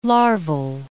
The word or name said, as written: larval